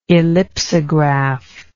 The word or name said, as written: ellipsograph